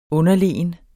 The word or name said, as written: underlegen